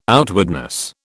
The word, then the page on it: outwardness: British English